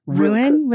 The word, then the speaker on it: Ruan: Ruin as in ruin, like R-U-I-N, but it's spelled yeah. R-U-A-N. Some people think it's Ruan, which is fine. I don't mind.